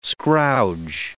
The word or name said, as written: scrouge